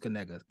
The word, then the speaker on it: Knigga: It's pronounced Kaniga. K-N-I-G-G-A.